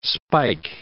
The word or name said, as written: spike